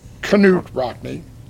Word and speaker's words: Knut Rockne: We're letting Knut Rockne, the proper pronunciation.